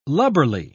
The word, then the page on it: lubberly: British English